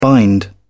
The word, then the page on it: bind: British English